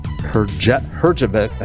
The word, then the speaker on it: Herjavec: Hear from Robert Herjavec. I cannot get his name right to save my Herjavec. – Life. Herjavec.